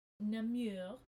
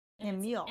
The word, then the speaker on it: Namur: Namur. – Namur. – N-A-M-U-R.